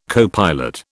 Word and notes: copilot: British English

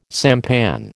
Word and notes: sampan: American English

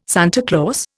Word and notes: Santa Claus: Indian

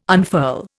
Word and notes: unfurl: Indian

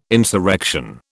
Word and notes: insurrection: British English

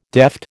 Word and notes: deft: American English

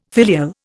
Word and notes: filial: Indian

